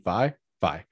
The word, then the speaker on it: Vi: or V Vi Vi Vi V. Uh, anyway, in Arcane, I'm, I'm pronouncing that wrong. I've already forgotten. Like, um, Vi. help me out here. – Vi, Vi. yeah.